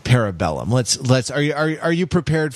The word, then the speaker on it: Parabellum: Parabellum. – Parabellum. Yeah. Now, parabolas. – Literally, parabellum, right? It should be two words. And it should be pronounced like Latin, which is what it is.